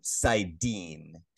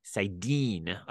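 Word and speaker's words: saidin: Sidar is correct. Just okay. Pronunciation, Sidin. – Sidin.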